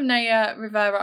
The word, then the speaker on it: Naya Rivera: But what's happened with Naya Rivera, I've said the name right.